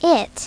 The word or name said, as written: it